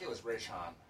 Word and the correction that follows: Raishan: Raishan. Okay. – R A I S H A N.